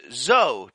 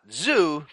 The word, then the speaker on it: zoology: Zoology has two O's and not three. Therefore, the correct and accepted pronunciation is zo, to rhyme with blow or toe, and not zoo, to rhyme with two, poo, or doo-doo, end quote.